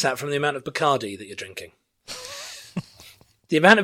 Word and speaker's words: Bacardi: Bacardi. Because it rhymes. The white rum of choice. – It rhymes with party, of course.